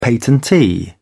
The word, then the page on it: patentee: British English